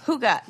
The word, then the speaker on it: hygge: How do you say that Scandinavian design Hygge. – Style that I love? Hygge. Yes.